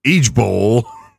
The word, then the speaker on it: IJBOL: Unlike the so-called strictly millennial LMAO, laughing my ass off, ROFL, rolling on the floor laughing, or LOL, IJBOL, pronounced each bowl, really, you had to include a pronunciation for each bowl, doesn't quite have the same je ne sais quoi.